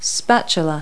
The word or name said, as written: spatula